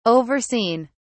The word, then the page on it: overseen: British English